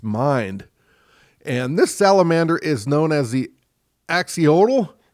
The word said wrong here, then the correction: axolotl: I think it's axolotl. – Axolotl, okay. – You know, there's a Y in there that's a bit weird, so okay.